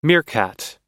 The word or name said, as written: meerkat